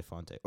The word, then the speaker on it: Fonte: Is it Jose Fonte or Jose Fonte? Or Jose Font? – It's Font.